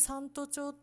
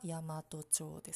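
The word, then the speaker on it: 山都町: まあ、たまに三都町って読まれたりもするんですけど、読み方は大和町ですね。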